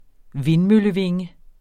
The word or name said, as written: vindmøllevinge